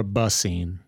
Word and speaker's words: Bassine: Yeah, she put the emphasis on the first syllable where it should be a little bit more subtle. It's more Bassine. – Yeah. – And you don't need to elongate that first syllable.